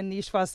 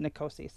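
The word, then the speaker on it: Niçoise: We used to have a Nichoise salad on our menu at Good Time, and people called it Nicosi.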